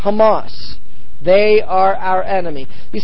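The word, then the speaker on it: Hamas (חמס): The word violence, this is Strong's number 2555, and the way you pronounce the word in Hebrew is Hamas.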